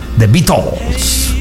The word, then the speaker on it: The Beatles: Tenía pronunciación inglesa. – Sí. – Lo tiene pues todavía, ¿no? – The Beatles.